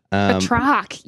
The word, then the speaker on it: Batroc: So if you all don't know what we're talking about, because we can't pronounce his name, Georges Batroc, maybe. – Batroc, yes.